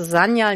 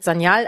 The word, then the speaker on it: Sanyal: Genau, es ist ein weiches S wie Sonne am Anfang und ansonsten, und es ist die Betonung auf der ersten Silbe, also Sanyal, nicht Sanyal, aber ansonsten ist es alles toll.